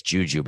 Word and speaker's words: JuJu: His name is J-U-J-U. How do you say his name? I was going to say it's Juju, but that seems not nice how to say the name. But that's how to say it. Okay. His name is Juju.